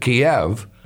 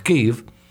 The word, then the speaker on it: Kyiv: We used to pronounce it Kiev, but now we understand it's pronounced Kyiv, which is, of course, the largest city and the headquarters of the government in the Ukraine.